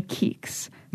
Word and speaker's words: Keeks: And I had this one friend, she would call me Keeks.